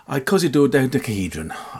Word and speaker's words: icosidodecahedron: So, icosidodecahedron. Yeah, that's how you pronounce it.